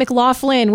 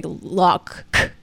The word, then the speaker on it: McLaughlin: Yeah, it's not McLaughlin with the sound. We got to get it right, guys. If we are going to say it correctly, the UK way, we got to say Loughlin.